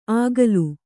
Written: ಆಗಲು

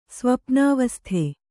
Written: ಸ್ವಪ್ನಾವಸ್ಥೆ

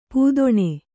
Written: ಪೂದೊಣೆ

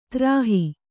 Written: ತ್ರಾಹಿ

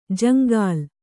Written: ಜಂಗಲಿ

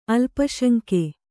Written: ಅಲ್ಪಶಂಕೆ

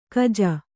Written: ಕಜ

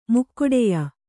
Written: ಮುಕ್ಕೊಡೆಯ

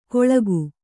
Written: ಕೊಳಕು

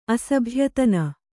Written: ಅಸಭ್ಯತನ

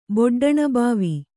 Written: ಬೊಡ್ಡಣಬಾವಿ

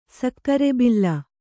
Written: ಸಕ್ಕರೆಬಿಲ್ಲ

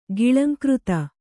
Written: ಗಿಳಂಕೃತ

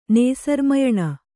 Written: ನೇಸರ್ಮಯಣ

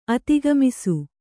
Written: ಅಧಿಕಮಾಸ

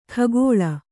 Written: ಖಗೋಳ

